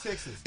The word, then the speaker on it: Techsys: Texas. It's spelled TECHSYS. But I think it's intentional that they call it Texas.